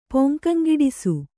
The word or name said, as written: ಪೊಂಕಂಗಿಡಿಸು